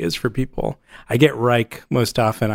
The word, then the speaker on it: Reich: Do you prefer Reich or Reich? – Reich. – Even though you spell it with an E.